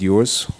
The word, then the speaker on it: Ewers: Like I said before, I'm Pastor David Ewers with Maranatha. My last name is spelled E-W-E-R-S.